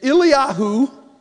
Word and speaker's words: Eliyahu: 1 Kings chapter 17, a passage of scripture that I think if Eliyahu, which is the Hebrew pronunciation of Elijah,